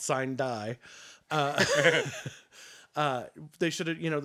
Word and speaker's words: sine die: No, because the I's are pronounced E's and the E's are pronounced E, so it would be Sine Die.